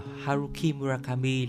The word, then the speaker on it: Haruki Murakami: Dạ vâng thưa quý vị, Haruka, à, Haruki Murakami là tiểu thuyết gia có sách bán chạy nhất Nhật Bản đương thời.